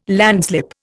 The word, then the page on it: landslip: Indian